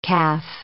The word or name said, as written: caff